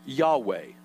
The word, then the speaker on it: Yahweh: It's pronounced Yahweh. Y-A-H-W-E-H, Yahweh. See, as the scribes and transcribers began to translate and rewrite the scriptures, and this word was considered to be so holy.